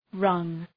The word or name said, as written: rung